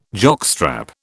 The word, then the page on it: jockstrap: British English